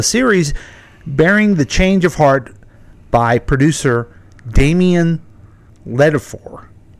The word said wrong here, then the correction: Damon Lindelof: Am I saying that right? – Yeah. Damon Lindelof.